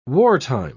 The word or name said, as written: wartime